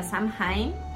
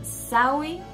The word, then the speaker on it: Samhain: lo pueden pronunciar Samhain o Samhain. en algunos lugares dicen Sawi, eh, Sawen, em, ¿cómo más dicen? Sound.